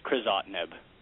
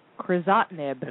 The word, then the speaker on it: crizotinib: The trade name is Xalkori, and the generic, I'm sure you'll probably hear from Dr. Pinnell later, is Crizotinib. – Crizotinib.